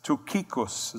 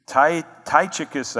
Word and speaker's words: Tychicus: Tukikos is that literal pronunciation from the Greek. Tychicus, I guess, is how we say it in English. But there's really no Y in the Greek. That's from the English transliteration.